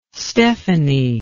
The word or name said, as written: stefanie